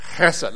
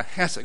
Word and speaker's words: Hesed: 这个都是 hesed love，哈瑟德的爱。我哋试下读好唔好啊？Hesed 咧，咁个 Hesed 嗰个字咧，系用个喉咙啊，嗰啲你听嗰啲犹太人讲嘢喉咙发音出嚟嘅 Hesed，得唔得啊？